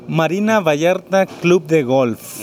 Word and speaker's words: Marina Vallarta Club de Golf: I don't say it properly. Do you say it? – Marina Vallarta Club de Golf.